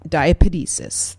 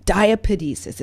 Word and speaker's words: diapedesis: So another thing that happens, though, when you've got this inflammation is something called, all right, wait for it, diapedesis. That's right. I'm going to spell it for you. Just, I love this word, diapedesis.